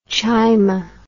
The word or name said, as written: Chaima